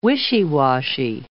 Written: wishy-washy

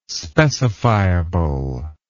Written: specifiable

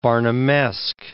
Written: Barnumesque

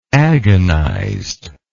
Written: agonizedly

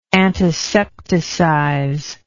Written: antisepticize

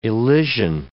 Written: elision

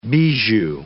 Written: bijou

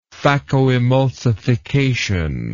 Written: phakoemulsification

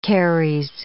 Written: caries

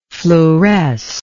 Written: fluorescer

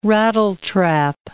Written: rattletrap

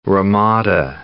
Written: ramada

